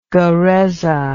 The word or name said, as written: guereza